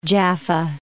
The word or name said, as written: Jaffa